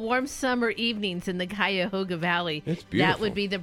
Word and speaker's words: Cuyahoga: Yeah, now in Ohio, we have Cuyahoga. – It's a river. – All of us from Ohio would know how to pronounce it.